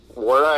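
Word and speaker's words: Nwora: My absolute dream would be Jordan Wara, I think is how you say his name. It's a silent N.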